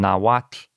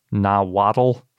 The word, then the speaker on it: Nahuatl: But the hard part is spelled starting with a TL, and it's based on Nahuatl, which is as best I can pronounce the language that we usually pronounce Nahuatl. – So we've been doing it wrong. – Yes. So it's really just two syllables, and it ends in that sort of soft T sounding thing. Really what it is it ends in what's called a lateral fricative, which isn't in a lot of widely spoken languages.